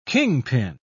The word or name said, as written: kingpin